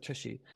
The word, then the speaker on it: TSHI: So anyway, it's a TypeScript hybridizer, TSHI. That's the actual pronunciation. Ooh, dirty minds out of the gutter. – I would have never got that. – It's right there in the readme at the top. It's like TypeScript hybridizer, Tushy.